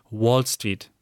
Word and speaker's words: Wall Street: Und er hat uns noch einen kleinen Tipp für die englische Aussprache mitgegeben, dass man nämlich W bei Wall Street nicht wie W spricht, sondern so ein bisschen wie einem kleinen O geformt, die Lippen. Also Wall Street.